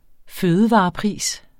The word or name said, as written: fødevarepris